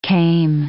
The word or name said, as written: kame